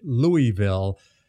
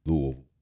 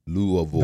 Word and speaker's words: Louisville: because most people, when they see the name, they inevitably pronounce it Louisville. Help them out, man. That is not at all how the name is pronounced. Tell them how to pronounce the name correctly. – If you're watching the video podcast, watch my lips. If you're listening to the show, an audio listen. Louisville. Louisville.